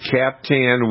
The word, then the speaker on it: Captan: One of the products is called Cap Tan, and it's spelled C-A-P-T-A-N.